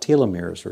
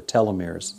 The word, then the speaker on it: telomeres: called telomeres, or telomeres, it can be pronounced either way.